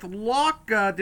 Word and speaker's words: Lauca: I'm here with Lauca.